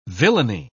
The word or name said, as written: villainy